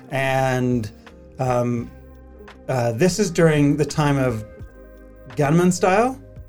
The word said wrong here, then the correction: Gangnam Style: Is that how you say it? – Yeah, I think so, yeah. Yes. Gangnam Style.